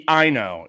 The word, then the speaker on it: Ino: I think it's just Eno, I N O, or Yuki Ino. It's Ino.